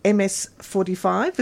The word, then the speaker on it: Ms. 45: Let's go to the another anniversary, this time the 45th, for Abel Ferrara and Zoe Lund's film MS-45. Is that how you pronounce it? Ms. 45. – Ms. 45. Mm-hmm.